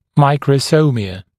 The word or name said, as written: microsomia